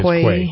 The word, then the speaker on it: quay: You know, well, is that how you say it? – It's quay.